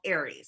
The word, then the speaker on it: Ares: And it's called Ares, A-R-E-S. And it's pronounced Ares as in the god of war.